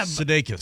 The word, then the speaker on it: Sudeikis: How do you say his name? – Yeah. – Sudeikis.